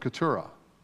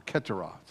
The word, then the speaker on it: Keturah: or the six sons of Keturah, or Keturah, sort of depends on where you want to pronounce it.